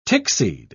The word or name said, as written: tickseed